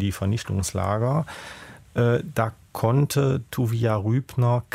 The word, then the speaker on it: Rübner: Tuvia Rübner oder Riefner, Riefner ist die hebräische Aussprache, hat darauf gerne mit Humor reagiert und sich als hebräisch-deutschen Schriftsteller bezeichnet.